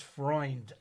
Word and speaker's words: Freund: You know, if somebody looks at me and says Jim Freund, I get pretty upset. It's Freund. Thank you. It's not Freund.